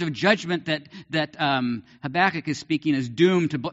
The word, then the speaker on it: Habakkuk: How do you pronounce it? Habakkuk, yeah.